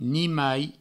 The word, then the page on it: nimai: ni-mai